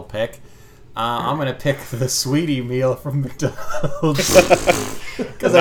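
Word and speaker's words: Saweetie: I think it looks like it's spelled Sweetie, but I believe it's Sweetie.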